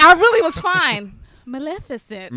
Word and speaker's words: Maleficent: Speaking of Magical Girls, I got to see Maleficent. Am I pronouncing that one right? – Maleficent.